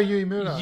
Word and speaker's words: Uemura: it was bringing up some book. Yuya Uemura. – Uemura? I don't think so. – That's how you say it. Yuya Uemura.